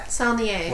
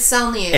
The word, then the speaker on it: Saulnier: Is it French? – I think it's just Saulnier. – It's Saulnier. – If it's Saulnier, it's definitely French.